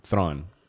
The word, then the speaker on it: Thrawn: Thrawn. Thrawn.